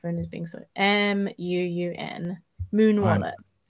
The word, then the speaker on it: Muun: If you have your phone, uh-huh, and you go download an app called Moon, confusingly, it's spelled M-U-U-N. It's one of my favorite. – M-U-U-N.